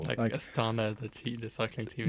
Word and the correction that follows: Astana: Yeah, like Astana. Notice I corrected your pronunciation sort of passive-aggressive way.